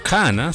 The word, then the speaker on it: Qhana: Cana se escucha o se escribe Q-H-A-N-A, Cana, que significa amanecer.